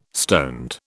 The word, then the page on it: stoned: British English